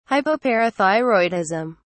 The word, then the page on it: hypoparathyroidism: British English